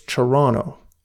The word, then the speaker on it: Toronto: But what I'm trying to say is, it never really hit me until now that he says Toronto like that. Toronto. Say it like it should be said, Herb. Toronto. It's Toronto.